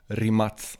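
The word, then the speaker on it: Rimac: Rimats ska det väl uttalas ja. egentligen. Ja. Har jag för mig i och med att den kommer från Kroatien.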